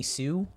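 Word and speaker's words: Hsu: Stephanie Sue, I think is how you say her last name. – That looks right. It sounds right, yeah.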